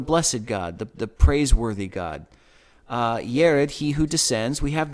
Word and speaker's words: Jared: Now, in the King James Version, this looks like it should be pronounced Jared. But it comes from Strong's Concordance number 3382. And it is pronounced Yered.